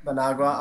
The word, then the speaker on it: Managua: Yeah, Managua is correct. – Managua.